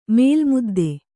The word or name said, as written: ಮೇಲ್ಮುದ್ದೆ